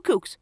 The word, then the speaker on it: CUC: It took it cost like one kook. that was another thing. they, the currency would be CUC, but they pronounced it kook, so it's like that's two kooks, which to me sounds like kook is like a slang word for a crazy person.